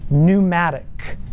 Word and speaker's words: pneumatic: It's pneuma, P-N-E-U-M-A, or pneuma, if the P is silent. It's where we get the word pneumatic, spelled with a P, the P is silent. As opposed to something that's hydraulic, hudor, hydro, water, or oil, we have pumps that are pneumatic, that is filled with the wind.